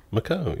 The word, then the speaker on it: Macau: Macau, is that? – Macau, yeah.